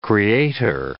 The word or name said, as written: creator